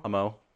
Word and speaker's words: Amo: A-M-O. – Isn't that, how do you say it? What's the Spanish word for love? – Yeah, it's like love.